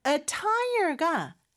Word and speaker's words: attire: Attire가 복장이라는 뜻입니다. So, 우리 자동차에 다는 타이어 있잖아요. 그거 하나랑 발음은 똑같아요. – Attire. – Attire. 네, 그런데 스펠링은 어떻게 되죠? – A-T-T-I-R-E.